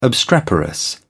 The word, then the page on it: obstreperous: British English